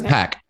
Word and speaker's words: Anderson .Paak: Am I saying his name right? I always question it. Yes, it is .Paak. It is .Paak. – Exactly. Because I'm having the whole throwback to Pac and everyone used to say .Paak. Yeah. And I'm like, but that's not how you say it. It's Pac.